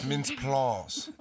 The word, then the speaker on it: Mintz-Plasse: I believe it's pronounced Mintz-Plasse.